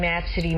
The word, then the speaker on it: Abcde: I've seen that name Absidy many a times. I work in HR, and we have quite a few employees with that name. At first, I didn't know how to pronounce it because I saw ABCDE.